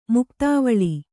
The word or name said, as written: ಮುಕ್ತಾವಲಿ